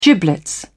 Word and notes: giblets: British English

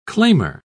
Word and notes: claimer: British English

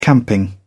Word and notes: camping: British English

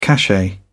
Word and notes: cachet: British English

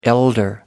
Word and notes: elder: American English

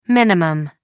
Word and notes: min: American English

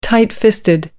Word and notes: tight fisted: American English

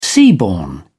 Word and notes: seaborne: British English

